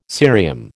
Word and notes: cerium: American English

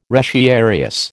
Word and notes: retiarius: American English

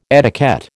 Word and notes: etiquette: American English

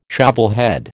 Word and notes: shovelhead: American English

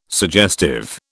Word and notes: suggestive: British English

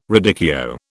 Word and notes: radicchio: British English

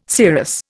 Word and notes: serous: Indian

